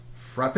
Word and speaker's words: frappe: We've got Frappe Day. And it says whether your accent is on the E, Frappe, or okay. You use a silent E. So I guess it would just be Frap.